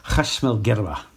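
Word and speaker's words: Khashm el Girba: Now, this was quite a journey because to get to this particular town that was called Kash, or if I try and do the Arabic pronunciation, it's Khashmal Girba, or we used to call it Gerber, in fact.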